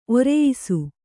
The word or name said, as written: ಒರೆಯಿಸು